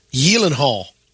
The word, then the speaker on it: Gyllenhaal: Should be Gyllenhaal.